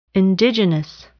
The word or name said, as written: indigenous